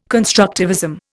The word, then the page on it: constructivism: Indian